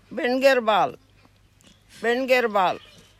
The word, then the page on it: bingirrbal: Gija